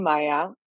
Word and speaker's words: Maya: So I'm Maya. That you said it right.